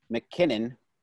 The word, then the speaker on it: MacKinnon: Old Ops guy Halpern had the third pick, and he couldn't be here, so he took in Nathan MacKinnon from the Avalanche.